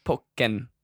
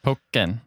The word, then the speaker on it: Pokkén: But in the Japanese style, okay. That would be pronounced Pokkén. – Pokkén. Okay, well, we'll do that then. Anyway. – Yeah, but I think that's probably why people have been saying Pokkén. – Pokkén. – Like, pocket.